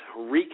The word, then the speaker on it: wreak: I said wrecked havoc. I meant wreak havoc. I'm pronouncing it wrong. Wreak, not wreck.